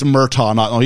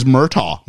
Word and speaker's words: Murtaugh: A bunch of people got a hold of me and said, it's Murtaugh. He's going, no. So it apparently is Murtaugh, not Murtaugh.